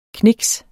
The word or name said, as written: kniks